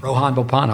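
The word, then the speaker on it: Rohan Bopanna: Rohan Bopana.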